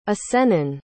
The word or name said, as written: acennan